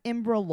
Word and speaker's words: IMBRA: IMBRA law. Is that how you say it? – Yes.